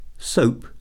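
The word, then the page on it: soap: noun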